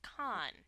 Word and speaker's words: Cannes: No, you don't pronounce the S at the end. – Say it like the chef. – It's French. It's Cannes.